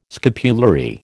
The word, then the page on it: scapulary: American English